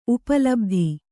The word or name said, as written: ಉಪಲಬ್ಧಿ